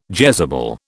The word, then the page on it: Jezebel: British English